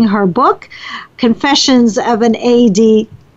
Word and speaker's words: ADDiva: Linda, did I get it correct this time? – Well, it was close. It's ADDiva.